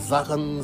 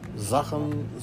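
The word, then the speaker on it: Srđan: 1965 muere el actor yugoslavo, la fregada, Zahan Zika Todorovic. Se escribe SRD, imagínense, SRDJAN, ¿sí? Entonces es Zhang, la R no sé dónde suena, es Rahan o cómo está la cosa, pero es Zahan Zika Todorovic, famoso por protagonizar la película Underground del director Emir Kusturica.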